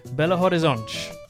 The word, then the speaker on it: Belo Horizonte: I spoke to Brazilians and this is how you pronounce it. Belo Horizonte.